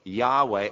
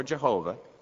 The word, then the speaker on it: Yahweh: Yahweh or Jehovah, there are different pronunciations, and you can pronounce it either way. But uh, I tend to go with Yahweh.